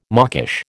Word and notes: mawkish: American English

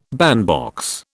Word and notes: bandbox: British English

